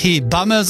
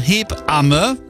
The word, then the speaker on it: Hebamme: Und ja, wir müssen uns beim Aussprechen von Hebamme richtig Zeit lassen in der Mitte. Das heißt ja nicht Hebamme, sondern Hebamme.